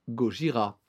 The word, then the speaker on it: Gojira: Du coup, le groupe change pour Gojira, qui est la prononciation japonaise de l'animal.